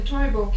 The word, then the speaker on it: Toivo: T-O-I-V-O. – T-O-I-V-O, Toivo.